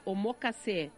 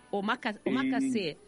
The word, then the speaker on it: omakase: que a palavra é omokase. Você sabe o que é? – Eu estava até falando errado aqui, eu estava tentando falar omokase. Omakase.